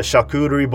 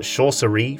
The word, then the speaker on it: charcuterie: The charcuterie board is there because for most of my life, I'd mispronounced it, and now just the word makes me laugh. I used to call it chaucerie for some reason.